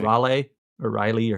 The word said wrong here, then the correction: Rayleigh: or how you pronounce his name? – Rayleigh.